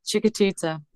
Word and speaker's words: Chiquitita: And the one last thing, the song Chiquitita. I did pronounce that right, didn't I? – Yeah, Chiquitita, that's it.